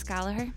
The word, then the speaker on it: Gallagher: I am delighted that I'm the first girl, and Cliff, it's Gallagher. So the second G is silent, but some people do pronounce it Gallagher, or Gallagher, so no worries.